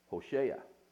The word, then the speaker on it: Hosea: So anyway, the name of the prophet Hosea is actually pronounced in the Hebrew, Hosea.